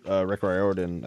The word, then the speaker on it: Rick Riordan: But so Disney kind of have the rights to it now, and they let Rick Riordan, I think that's how his name is pronounced.